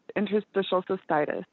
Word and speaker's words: interstitial cystitis: I hope I pronounced that right. – Yes, you take a minute to learn it too. It's interstitial cystitis.